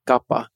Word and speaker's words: Kapha: And if you want to know the, the correct pronunciation for Kapha, it's actually Gappa.